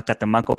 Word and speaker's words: Catemaco: Tiene que ir a Catemaco para que se lo hagan. ¿Qué dijiste? Que tiene que ir a, Catemaco. Lo dije bien la primera vez y trabarme, y gracias a que me cuestionaste, vali verga.